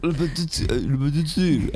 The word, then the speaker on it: Le Petit Cirque: Le Petit Cirque. Hay que hablar así como con la boca medio cerrada. y con la nariz por arriba.